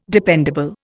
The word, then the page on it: dependable: Indian